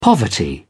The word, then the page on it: poverty: British English